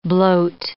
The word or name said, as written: bloat